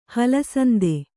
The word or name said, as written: ಹಲಸಂದೆ